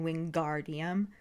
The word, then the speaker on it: Wingardium: Because in the book, she tells him to make the gar nice and long in Wingardium.